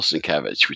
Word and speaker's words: Sienkiewicz: And at the top, it said, and I see this wherever I see him, Sin, and then in big letters, Kev, and Itch at the end, Sienkiewicz. And I said, oh, that's how you pronounce it.